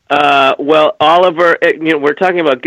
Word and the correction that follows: Olivier: I think it's Olivier, by the way.